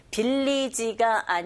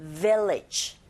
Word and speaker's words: village: 빌리지가 아니라 여러분 발음하실 때 윗니로 아랫입술 긁으시면서 village 그리고 people 뒤에 푸 자체가 된소리 현상이 일어나서 people 그리고 pray.